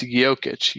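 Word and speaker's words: Jokić: And I guarantee you the Spurs, and they did, they shut down Djokovic. I mean, did I say that right? Jokic? – Yeah. It's Jokic. Djokovic is a tennis player.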